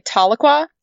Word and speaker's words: Tahlequah: Tahlequah? Is that how you pronounce that? – I would say Tahlequah.